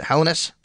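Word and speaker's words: Helenius: Is that how you say it? Hellenius. Thank you. The I is, I guess, silent there.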